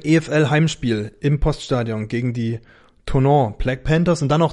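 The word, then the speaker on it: Thonon: Und ähm, als dritte Partie auf dem Spielplan steht dann Anfang Mai EFL Heimspiel gegen die, wie ich mir von äh, vom Centre Français habe sagen lassen, Tonon, werden die guten ausgesprochen, Tonon Black Panthers.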